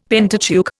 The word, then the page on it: Pentateuch: Indian